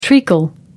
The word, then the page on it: treacle: American English